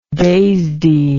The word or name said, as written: DASD